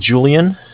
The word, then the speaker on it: Julian: All right, my name is Julian. It's J-U-L-I-A-N.